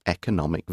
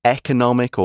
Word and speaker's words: economic: This is a national treasure due to its economic value. And you can say economic or economic, both ways of pronouncing this word are absolutely fine economic or economic, it's an adjective relating to the economy, which is all the production of goods and services.